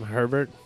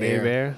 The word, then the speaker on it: Herbert: Is it because they pronounce his last name Herbert? – Instead of A-Bear? – That's part of it.